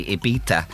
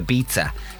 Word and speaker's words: Ibiza: Raphael was onto us, and he says it's funny when people say Ibiza instead of Ibiza. It's just like pizza.